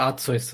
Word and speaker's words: Arceus: Ich würde Arceus sagen. Ich weiß, das hat mir jetzt schon öfter, ich bleibe auch bei Arceus.